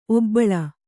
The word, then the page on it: ಒಬ್ಬುಳಿ: obbuḷi